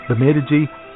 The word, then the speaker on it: Bemidji: And then, and well, not then, I mean now, right now, today, this afternoon, down at the Bemidji, I said it right, Bemidji Public Library is hosting a slam poetry competition at the Keg and Cork.